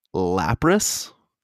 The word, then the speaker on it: Lapras: JMO, you got Lapras.